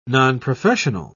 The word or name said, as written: nonprofessional